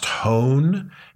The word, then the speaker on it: Hoehn: It's written by a man named Charlie, and I think the name is pronounced Hoehn. If I'm wrong, forgive me, Charlie, or anyone else who knows him. H-O-E-H-N. Charlie, spelled with I-E, Hoehn, H-O-E-H-N, I think is how it's pronounced.